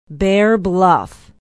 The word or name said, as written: bear bluff